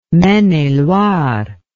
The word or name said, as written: Maine-et-Loire